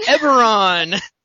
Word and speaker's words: Eberron: Eberron.